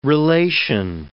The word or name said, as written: relation